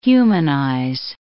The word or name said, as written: humanize